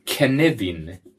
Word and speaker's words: Cynefin: Och det stavas synefin, men uttalas Knevin. – Nej. Jo. – Det stavas alltså C-Y-N-E-F-I-N, men uttalas Knevin.